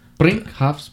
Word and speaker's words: Brinkhoff's: Brinkhoff's Brewery. – How do you spell that? – B-R-I-N-K-H-O-F-F.